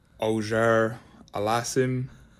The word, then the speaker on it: Auger-Aliassime: Auger Aliassime, Auger Aliassime, Auger Aliassime, Auger Aliassime, Auger Aliassime. So the proper way to say it is Auger Aliassime.